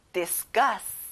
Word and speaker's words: discuss: 여기는 끝에 gus의 강조를 넣어보세요. Discuss.